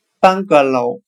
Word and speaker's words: bungalow: 今天所学的单词 “bungalow” 平房，我们就可以通过它的发音联想到汉语的“ 半个楼”，半个楼房，那就是平房了。 bungalow。